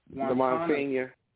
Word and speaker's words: Monsignor: As a sign of hope for the people of Maui, the Reverend Monsoor, I think is how they pronounce that, Terrence Monsignor.